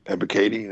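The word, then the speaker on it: Ebiketie: Is it Ibikati? – I think it's like Ebikati. – Ebikati. That's how you say it.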